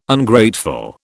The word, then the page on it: Ungrateful: British English